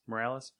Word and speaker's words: Morales: How do you say his last name? Morales? – Morales.